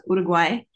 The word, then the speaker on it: Uruguay: It's pronounced Uruguay.